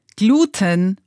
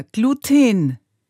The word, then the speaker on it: Gluten: Gluten an sich ist Klebeeiweiß, wird auf der letzten Silbe betont und jede Betonung auf der ersten Silbe ist schlichtweg ungewöhnlich und letztlich auch falsch.